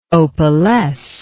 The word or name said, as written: opalesce